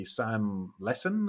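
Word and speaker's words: Sam Lessin: For people who missed it, the progression was basically Sam Lesson. Is that how you pronounce his name? – Yeah, you need to start with, because most people don't use Calendly.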